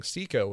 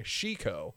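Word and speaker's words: C-ko: So you'll notice that if you pay attention when they're saying Siko, whenever they say her name, they're saying Shiko, because they can't, because that syllable in Japanese has to be pronounced that way, of she instead of C.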